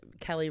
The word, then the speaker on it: Kelly: I think I've talked about this before because Kelly rhymes with Smelly. – Smelly, belly, jelly, all of those things.